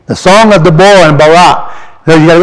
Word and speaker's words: Deborah: On that day, Deborah, Deborah, that's the way it's pronounced in the Hebrew, and Barak, son of Abinoam, saying, for leaders leading in Israel, for the people volunteering, bless Jehovah.